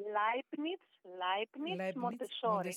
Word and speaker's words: Leibniz Montessori: Μία πάρα πολύ αξιόλογη και δυναμική εκπαιδευτικός εδώ και πάρα πολλά χρόνια στο σχολείο, δεν ξέρω αν θα το προφέρω σωστά, Leibniz Montessori. – Ναι, είναι το Leibniz Montessori Γυμνάσιο, είναι το Μοντεσοριανό, λέω εγώ πάντα, καλύτερα βγαίνει πιο εύκολα, το Μοντεσοριανό Γυμνάσιο Leibniz στο Ντίσσελντορφ.